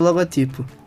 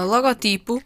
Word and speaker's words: logotipo: A forma tradicionalmente correta é logótipo, palavras de ou seja, acentuada na antepenúltima sílaba. No entanto, a forma logotipo é uma palavra grave cujo uso se generalizou. Por isso, ambas são aceites, mas tal como já foi referido, deves privilegiar a forma logotipo.